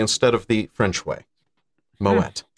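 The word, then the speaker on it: Moët: Oh, Moet. The reason it's not pronounced the French way, I Googled this, I was trying to figure out.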